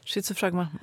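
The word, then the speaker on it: Schizophragma: Ja, Tänk diafragma. Schizo. Schizofragma.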